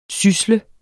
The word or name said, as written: sysle